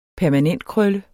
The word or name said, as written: permanentkrøl